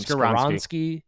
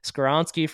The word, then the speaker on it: Skoronski: Skoronski, Skoronski from Northwestern.